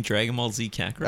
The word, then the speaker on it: Dragon Ball Z Kakarot: Let me see if I'm pronouncing this right. Drodon Bale Zed Kakarot. – Are you saying Dragon Ball Z Kakarot? – Oh, all right.